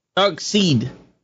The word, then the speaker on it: Darkseid: Yo, but it's Darkseid. – Darkseid. There you go. I just didn't say it right. – It S-E-I-D.